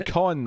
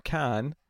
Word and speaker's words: Caan: Con. – Just like think it's like an O. – A con. – Yeah, there you go. – That's what I'm saying, con, though, not can.